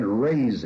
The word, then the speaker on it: rase: Remember, O Lord, the children of Edom in the day of Jerusalem, those who said, raise it. We would spell it R-A-Z-E, but in the King James Version it's spelled R-A-S-E.